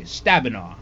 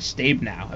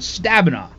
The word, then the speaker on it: Stabenow: That's how you say it, apparently. Stabenow. I thought it was Stabenow. I mean, who really cares? But Stabenow.